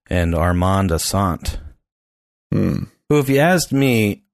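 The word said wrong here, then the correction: Assante: Asante. – Asante? Is that how you say it? – Yes.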